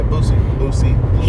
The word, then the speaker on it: Boosie: Yeah, Boosie.